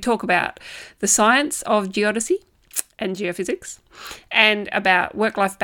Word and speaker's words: geodesy: I actually had to listen to the podcast again to make sure I was pronouncing that correctly. Geodesy is what it is.